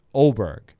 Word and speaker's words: Åberg: He went right around the corner to Åberg or Åberg, but in Swedish that has a special symbol over the A, and so it's pronounced Åberg.